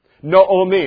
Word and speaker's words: Naomi: Naomi is actually how you pronounce her name, but she clung to her.